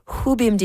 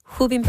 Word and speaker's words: Rubim: This would be a good moment to mention that Edmonton's Rubim de Toledo is originally from Brazil, where the letter R at the beginning of a word is often pronounced more like an H.